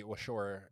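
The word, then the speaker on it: Waushara: So they actually find Ed Gein at a grocery store in the Washara County Sheriff's Department. Actually, I butchered that. So let me clap. So police locate and arrest Ed Gein at a grocery store, and then the Washore County Sheriff's Department searched Gein's farm that same evening.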